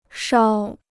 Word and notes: 梢: shāo

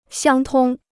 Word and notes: 相通: xiāng tōng

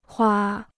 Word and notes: 花: huā

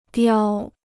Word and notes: 叼: diāo

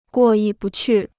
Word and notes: 过意不去: guò yì bù qù

